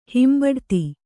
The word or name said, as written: ಹಿಂಬಡತಿ